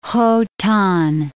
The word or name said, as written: Khotan